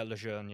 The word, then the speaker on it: Lejeune: Yeah, Lejeune, yeah.